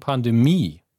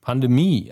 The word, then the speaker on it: Pandemie: Aber hier ist Betonung Pandemie. Wow, also der letzten Silbe. Pandemie.